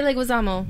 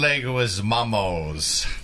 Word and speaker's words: Leguizamo: Say Leguizamo. – John Leguizamos. – Well, you always had an S. – Leguizamos.